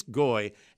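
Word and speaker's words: Ngoy: This is an international signing, as Auburn has signed a player from the French B League, Narcisse, and we think it's just Goy. I think the N is silent. So Narcisse Goy, a 7-footer who averaged a double-double, averaging just under 11 points, 11.5 rebounds, and over 2.5 blocks per game.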